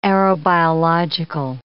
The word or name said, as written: aerobiological